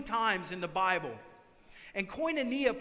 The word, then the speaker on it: koinonia: It's, it's koinonia. Say that, koinonia.